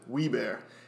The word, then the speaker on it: Wilbur: It was spelled the same way, but they pronounced it Wee-Bear.